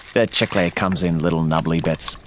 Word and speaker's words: chicle: Edward makes his gum from chicle, or chicle, as it's more commonly pronounced.